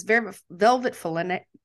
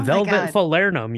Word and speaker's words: Falernum: So anyway, so you've got this rum, you've got chartreuse, green chartreuse, you've got this Velvet Falernum. Am I saying this right? – Oh, Velvet Falernum. You were so close.